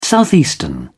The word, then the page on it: southeastern: British English